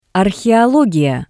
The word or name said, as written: Arheologiya